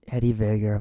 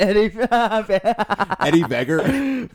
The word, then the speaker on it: Eddie Vedder: Eddie Vedder. I'm sorry. – You didn't even say his name right. Eddie Vedder.